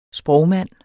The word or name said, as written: sprogmand